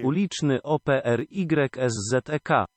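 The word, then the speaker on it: Uliczny Opryszek: If you want to hear the proper pronunciation of the band name, it is, well, in from Polish, it says the translation is street spraying. So who the hell knows what it translates to? – Uliczny O-P-R-Y-S-Z-E-K. – Yeah, I don't think I would have pronounced it that way, and I don't think I did. So there you go.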